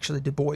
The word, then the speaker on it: Du Bois: Du Bois, Du Bois is how he pronounced it,